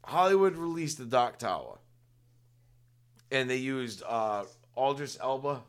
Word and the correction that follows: Idris Elba: Is that how you say his name? – I love that actor, dude. – Idris. – Idris Elba.